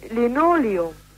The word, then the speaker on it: Linoleum: Und für Sie sagen wir mit, also ich für meinen Teil sage Linoleum. – Ja, und das ist auch amtlich richtig.